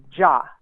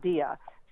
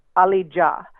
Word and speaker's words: Dia: And maybe if people were talking to people and trying to find him, you know, the pronunciation of his last name is Ja. It's not Dia. So it's Ali Ja.